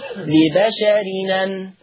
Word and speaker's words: لبشر أن: طبعا أنا كتبت التنوين مجازا حرف نون، لبشر أن تقرأ بالنقل، فلما انقل الهمزة المفتوحة يا إخوة يا أخوات للنون التنوين هتبقى نون التنوين منطوقة نون إيه؟ مفتوحة، فيصير النطق لبشرنا، لبشرنا. يبقى هكذا: ما كان لبشر أن تقرأ، ما كان لبشرنا هكذا. يبقى لبشر أن هتبقى نون مفتوحة فتقرأ لبشر، لبشرنا.